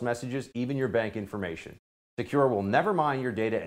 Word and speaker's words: Sekur: That's why you need Secure. It's spelled S-E-K-U-R.